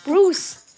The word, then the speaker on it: Bruce: B-U-R-U-C-E. Bruce. Eben. Mit E. – Ja, aber das E am Schluss spricht man nicht.